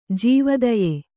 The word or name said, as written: ಜೀವದಯೆ